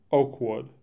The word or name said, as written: Oakwood